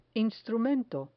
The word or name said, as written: instrumento